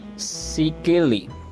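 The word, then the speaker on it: Székely: Se mudó a Manhattan en 1989 y él añadió el CK a su nombre artístico que eh, pues proviene de la pronunciación en inglés de su apellido, ...Sikili.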